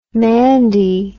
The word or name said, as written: mandie